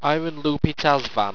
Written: ayrunlupitazvan

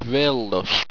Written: brailust

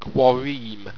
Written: qoireem

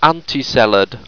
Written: antiseled

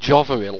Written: jovoril

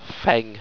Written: pheng

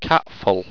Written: katful

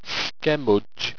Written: schemudge

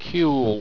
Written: cuyl

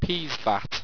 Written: peesvat

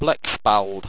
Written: blecspald

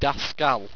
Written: dascal

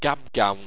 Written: gabgown

